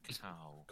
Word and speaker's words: cacao: Cacao. – Cacao. – Is that how you say it? – Yeah, that's the plant, because it's the plant. It's not cocoa.